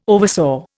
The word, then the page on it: oversaw: Indian